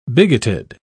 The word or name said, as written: bigoted